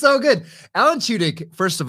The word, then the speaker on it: Tudyk: How do you say Tudyk. – His name? – Alan Tudyk. – Tudyk.